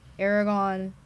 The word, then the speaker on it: Aragorn: I do enjoy, like, Aragorn and stuff like that. I don't think I said that right.